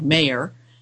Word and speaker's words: Mayr: He writes, in 1919, his Karl Mayer, that's Mayer is spelled M-A-Y-R, but it's pronounced like Mayer,